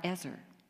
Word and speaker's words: Ezer: The same word helper, the original Hebrew word, is spelled E-Z-E-R, Ezer.